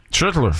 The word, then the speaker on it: Schlittler: Schlittler. J'aime ça de la manière que tu l'as très bien prononcé.